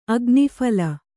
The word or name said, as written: ಅಗ್ನಿಫಲ